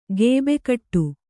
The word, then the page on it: ಗೇಬೆಕಟ್ಟು: gēbe kaṭṭu